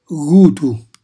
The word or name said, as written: gutu